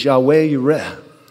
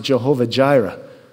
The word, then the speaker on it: Yahweh Yireh: All of us should know that one of God's names is Yahweh Yireh. Some pronounce it Jehovah Jireh, the God who provides.